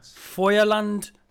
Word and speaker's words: Feuerland: Sorry, what's it called? – Feuerland. – Correcting me on my pronunciation as usual.